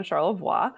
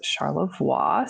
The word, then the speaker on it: Charlevoix: We Americanized it. It should have been Charlevoix, and named for the French Jesuit missionary. His last name was Charlevoix.